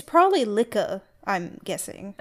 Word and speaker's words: Liche: It's Lich, apparently. – Okay. – The English pronunciation. Carl Lich.